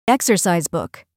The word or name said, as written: exercise book